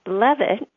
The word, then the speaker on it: Levitt: Levitt looks like it should be pronounced Leavitt, L-E-A-V-I-T-T, but actually it's a short E sound, like bread, thread, and dead.